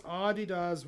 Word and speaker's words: Adidas: It's pronounced Adidas with emphasis in the first syllable. The brand is.